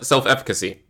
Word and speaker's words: self-efficacy: Did you say that right? – Yes, yeah, self-efficacy. Yeah, you said it correct.